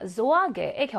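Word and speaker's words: Sorge: 2번 Sorge의 경우는 알파벳 G가 단어 중간에 있습니다. 이럴 경우에는 뒤에 나오는 알파벳의 종류를 구분해서 활용을 합니다 라고 했어요. 모음이 나올 경우 앞에 나와 있는 개는 그로 발음을 하게 돼요.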